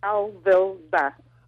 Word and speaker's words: Alvilva: it's in syllables, so it would be something like cha-cha-cha. So it's Alvilva.